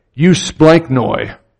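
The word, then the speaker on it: Eusplanknoi (εὔσπλαγχνοι): Eusplanknoi. That epsilon is pronounced you.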